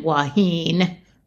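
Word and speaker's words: wahine: This just in, Waheen, that's how you say it.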